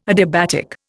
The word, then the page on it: adiabatic: Indian